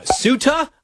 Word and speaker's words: Ceuta: We saw some pretty horrific footage out of Ceuta, I'm told it's pronounced, which is in Spain, which I'm told is actually secretly in Africa.